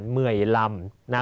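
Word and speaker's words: mười lăm: เมยลำนะครับสังเกตเลข 5 ถ้าเลข 5อยู่โดดๆจะใช้คำว่านำแต่ถ้าอยู่ข้างหลังจะออกเสียงว่าลำนะฮะ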